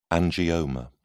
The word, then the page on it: angioma: British English